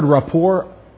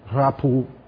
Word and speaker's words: rapport: Do you know that word, rapport? It can be pronounced rapport. Rapu, if you're French, rapu. That's the word. It's a French word. That's why I don't pronounce the T.